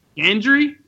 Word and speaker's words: Gendry: Gendry. – Like a hard G. Gendry. – Gendry.